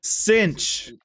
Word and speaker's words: Cinch: Cinch. – Is it Cinch? Okay, it is Cinch.